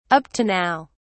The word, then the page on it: up to now: British English